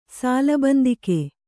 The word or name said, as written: ಸಾಲಬಂದಿಕೆ